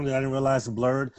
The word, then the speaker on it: blerd: B-L-E-R-D, blurred. – There you go, blurred.